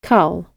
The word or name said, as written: cull